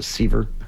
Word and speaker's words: Sevier: Well, and if you've ever been to Dollywood or you've been to Sevierville, which is, everyone says, it's Seaver. It looks like Sevier.